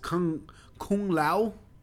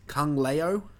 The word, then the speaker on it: Kung Lao: Daniel refers to him as, Kung Leo. He's going to hate me when he hears... No, he fucking hates it when people pronounce it like that.